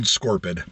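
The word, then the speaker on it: Scorpyd: Old Scorpid. It's pronounced Scorpid.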